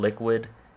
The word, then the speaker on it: Liqwyd: Shout out to artist called Liquid. It's pronounced, or not pronounced, but it's spelled L-I-Q-W-Y-D, and the track name is Sweet.